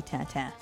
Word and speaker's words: Tintin: You know, I don't actually know if they say it differently, stylized-wise, but in terms of French pronunciation, it should be Tintin.